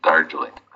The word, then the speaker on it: Darjeeling: Well, she was uh, she was on the train to uh, to Darlingen. Is that how you pronounce Darjeeling. it? – Darjeeling. Darjeeling. Okay.